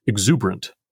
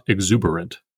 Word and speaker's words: exuberant: Similarly, in line 7, we are meant to pronounce exuberant, not exuberant.